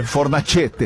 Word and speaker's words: Fornacete: Mas o cara não mandou como se pronuncia? – Rima com croquete. – Mandou. – Tá, e aí? – Como é que se pronuncia? – Fornacete.